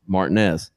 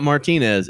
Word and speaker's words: Martinez: It's spelled Martinez. What do you think? How do you think it's pronounced? – Martinez. – Not Martinez. It's Martinez.